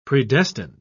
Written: predestined

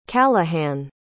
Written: callaghan

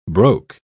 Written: broke

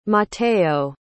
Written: matteo

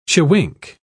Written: chewink

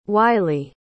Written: wiley

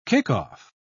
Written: kickoff

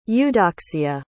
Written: eudoxia

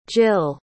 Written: jill

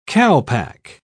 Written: calpac

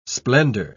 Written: splendor

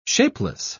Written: shapeless

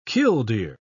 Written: killdeer

